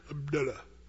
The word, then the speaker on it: Abdullah: When you go to Israel or Jordan and you meet people and they're called Abdullah, that you got to loosen up your lips and sort of say, Abdullah.